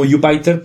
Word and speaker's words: Jupyter: Lo llamo Jupyter porque eh, la palabra es tres sílabas, que es Julia, Python y R al final.